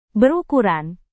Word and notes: berukuran: indonesian

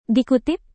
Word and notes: dikutip: indonesian

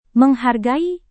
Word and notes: menghargai: Indonesian

